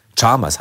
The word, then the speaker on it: Chalmers: Mrs. Chalmers, auch völlig falsch ausgesprochen, wie du mir erzählt hast, im Hörspiel ganz anders. Chalmers, Chalmers heißt es ja eigentlich.